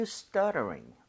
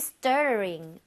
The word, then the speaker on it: stuttering: 여기 flap 나는 거 잊지 말고요. 리을 비슷하게. Why are you stuttering? Are you feeling guilty? – Why are you stuttering?